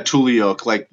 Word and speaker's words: tule: Yeah, tule elk. It's named after like the brush that they eat, the tules out in the marshlands.